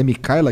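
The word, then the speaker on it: Mikhaila: É Micaela que fala mesmo? É, ela tem um nome esquisito. Então, ó, se escreve assim, ó. M-I-K-H-A-I-L-A. P-E-T-E-R-S-O-N.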